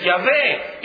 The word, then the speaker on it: Yahvé: Y es por eso que hay algunos que dicen que la pronunciación de esta palabra debe de ser Yahvé y no Jehová.